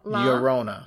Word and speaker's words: La Llorona: It's La Llorona.